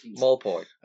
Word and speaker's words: Maupay: I never pronounced his name right. We'll give it to you. It's all right. – Maupay. Wonderful.